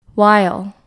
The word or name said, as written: while